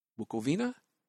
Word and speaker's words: Bukovina: The first of the 3 ballets is Songs of Bukovina. Bukovina?